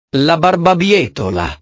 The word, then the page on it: la barbabietola: italiano